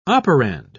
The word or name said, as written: operand